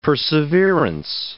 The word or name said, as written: perseverance